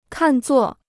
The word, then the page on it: 看作: kàn zuò